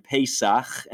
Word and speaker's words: Pesach: In the same way, Passover and Pesach, and I'll do the extra phlegm at the end so our audience can hear the way you'd pronounce it in Hebrew, are the same thing, even though they don't sound the same.